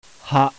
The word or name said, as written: HA